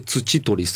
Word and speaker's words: 土取: を取得するためにはどうすればいいのかというお話を、はい、日本ライトハウス盲導犬訓練所の土取誠さんと電話がつながっておりますので、お話を聞いてまいります。土取さんです。こんばんは。 – あ、こんばんは。 – よろしくお願いいたします。 – よろしくお願いいたします。土取です。 – 土に取ると書いて鳥取の取ると書いて、これで土取さんという、珍しいお名前ですね、土取さんね。